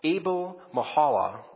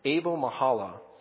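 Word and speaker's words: Abel-Meholah: Now, by way of background, he came from Abel-Mehalah. That's how I'm going to pronounce it. He came from Abel-Mehalah.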